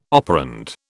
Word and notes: operand: British English

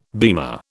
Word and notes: beamer: British English

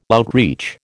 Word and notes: outreach: American English

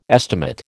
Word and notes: estimate: American English

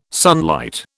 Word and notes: sunlight: British English

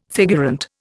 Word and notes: figurant: Indian

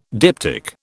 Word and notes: diptych: British English

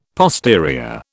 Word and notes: posterior: British English

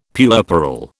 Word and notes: puerperal: British English

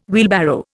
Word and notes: wheelbarrow: Indian

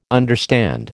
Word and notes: understand: American English

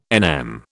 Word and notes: NM: British English

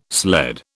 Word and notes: sled: British English

